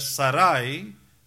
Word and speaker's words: Sarai: muchos hispanos mal pronuncian ese nombre, llaman de Sarai, pero es Sarai,